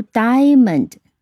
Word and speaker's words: diamond: 这个词读 diamond，它是重音在第一个音节。